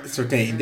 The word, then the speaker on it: Sartain: Sartin? Yeah, I'm sticking with that. Dr. Sartin tells him, like. – Yeah, Sartain. Sartain. Yeah, that sounds better.